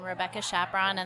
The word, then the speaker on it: Rebecca Chaperon: And on most of my social media, it's just my name, Rebecca Chaperon, and that's spelled with a C-H-A-P-E-R-O-N.